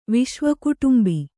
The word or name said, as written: ವಿಶ್ವಕುಟುಂಬಿ